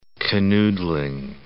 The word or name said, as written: canoodling